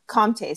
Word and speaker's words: Comté: Comté, COMTE. Is that right? – Yeah, with an accent on the E.